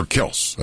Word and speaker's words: Kelce: All right, we are about to see a lot of Travis Kelce, or Kels, as their name is pronounced.